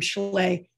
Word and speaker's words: Schley: The way my name is spelled is Sarah, no H, S-A-R-A. And then my last name, Schley, S-C-H-L-E-Y.